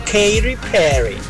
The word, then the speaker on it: Katy Perry: Ay, Katy Perry.